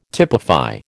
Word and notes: typify: American English

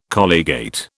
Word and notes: colligate: British English

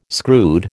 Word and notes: screwed: American English

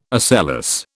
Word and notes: ocellus: British English

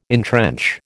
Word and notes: entrench: American English

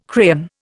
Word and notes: crayon: Indian

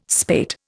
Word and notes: spate: Indian

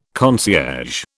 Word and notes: concierge: British English

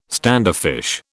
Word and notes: standoffish: British English

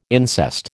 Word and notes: incest: American English

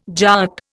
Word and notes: Jat: Indian

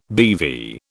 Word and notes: BeV: British English

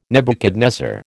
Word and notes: Nebuchadnezzar: American English

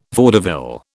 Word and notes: vaudeville: British English

